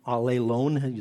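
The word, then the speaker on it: allelon (ἀλλήλων): There's a Greek reflexive pronoun, allelon, is how you say it, that is translated one another, and it's one of the most fascinating studies you can do.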